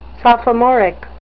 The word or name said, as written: sophomoric